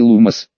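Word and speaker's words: Lumos: Теперь произнеси «Лумос». Нет же, латинское ударение, а не французское.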